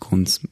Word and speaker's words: Grunz: und dann würde man deinen Nachnamen Grunz aussprechen, aber du sagst, James Grunz ist auch okay. – Ja, das ist halt in der Schweiz ist, ja, sagen wir halt James Grunz. Und ich sage mir selber ja auch James Grunz, weil der Name kommt aus dem Elsass ursprünglich. Das heißt, vielleicht wäre das sogar noch irgendwie so halb französisch ausgesprochen.